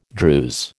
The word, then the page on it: Druse: American English